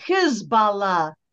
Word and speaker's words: Hezbollah: And by the way, I always say Hezbollah because in pronouncing it that way, you get the meaning of the word. Hizb, H I Z B in English. Um, the Arabic meaning of that is party. Allah, God. Allah is the Arabic word for God. So Hezbollah, the party of God. But if you say it any other way, you lose that meaning.